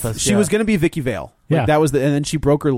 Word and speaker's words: Vicky Vale: If she was going to be Vicky Vale it's pronounced Vicky Vale. Vicky Vale.